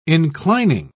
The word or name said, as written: inclining